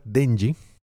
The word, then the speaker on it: Denji: Entonces, el protagonista, Denji, lo dije bien ahora sí, ¿verdad? Sí.